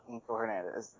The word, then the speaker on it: Hernandez: Do you prefer the more authentic pronunciation of your name, or is my white person speaking of it okay? – No, actually, I prefer the white version, because that's, yeah. – Okay. – It's when people, like, try to correct me, and they're like, oh, you mean Angel Hernandez? I'm like, no, I know my name, and it's Angel Hernandez.